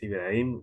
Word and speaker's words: Ibrahim: Pues bueno, eh, mi nombre es Ibrahim Rodríguez, la, la H no se pronuncia, amigo.